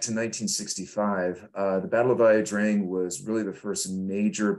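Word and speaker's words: Ia Drang: Oh, sorry, I can't pronounce it. See, say that again. La Drang. La Drang. – La Drang. – La Drang. Okay, sorry.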